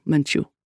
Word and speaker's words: Menchu: I am Father Arturo Menchu. accent on the second syllable.